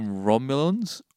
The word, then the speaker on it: Romulan: R-O-M-O-U. No. – You're putting an emphasis on the ons on the end, but it's Romulan. – Romulans. – Like penicillin. – Romulans. – R-O-M-U-N-A-L-S.